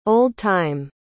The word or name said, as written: old-time